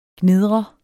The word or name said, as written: gnidret